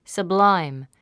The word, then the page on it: sublime: səbláim; サブライム